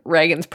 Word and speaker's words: Reagan: Actually, not to like touch on Reagan's presidency and comment on it in any way, but he has been useful because the spelling of his name is R-E-A-G-A-N, and it is pronounced Reagan, which has helped me greatly in my life